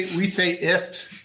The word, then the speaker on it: IFTTT: We say IFT. So kind of like a gift without the G.